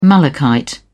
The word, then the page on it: malachite: British English